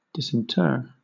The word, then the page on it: disinter: British English